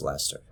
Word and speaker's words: Leicester: I got a bunch of, like, people told me it's pronounced Leicester. I hope I'm pronouncing it right.